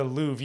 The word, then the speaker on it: Louvre: The Louvre.